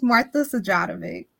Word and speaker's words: Sajatovich: I always mispronounce her last name. Sajatovich. How do I pronounce that name? Tell me how to best pronounce that name. It's a hard name to pronounce. – It is. It's Martha Sajatovich.